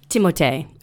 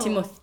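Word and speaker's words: Timothée: Okay, Timothy, which I recently found out within my deep searches is you actually pronounce his name like Timothe or something like that. – Oh. – Timothe or something.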